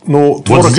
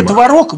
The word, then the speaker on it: творог: Творог и творог вот идут споры. Потому что в словаре будет написано, что нормативное ударение творог, а люди все произносят творог. И не исключено, что через какое-то время пройдет, и наши словари скажут, наши ученые придут к заключению, что теперь пора сделать нормой форму творог.